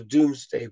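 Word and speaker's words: Domesday: So the Doomsday Book is two. Doom isn't spelt D-O-O-M. It's actually spelt like King's Dome in Seattle. It's D-O-M-E. Pronounced Doom, but it's not spelt Doom.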